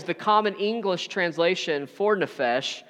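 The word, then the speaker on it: nefesh (נפש): And in the Hebrew, soul is pronounced nefesh. It's nefesh.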